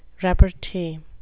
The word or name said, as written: repartee